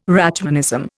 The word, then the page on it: Rachmanism: Indian